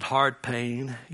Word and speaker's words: Howard Payne: We pronounce it Hard Payne University.